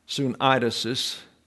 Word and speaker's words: sunaidesis: The Greek word is sunaidesis. The accent is on the diphthong. Sunaidesis is the pronunciation, the mental faculty by which one distinguishes between right and wrong.